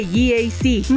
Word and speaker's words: GAC: No se dice GAC, se dice GAC, nuevamente GAC, y se maneja mejor de lo que se pronuncia.